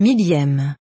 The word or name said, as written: 1000eme